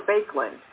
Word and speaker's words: Baekeland: Am I pronouncing it correct? Is it Bakelite or Bakelite? – Bakelite.